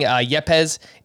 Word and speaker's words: Yepes: Yepes? – That's correct.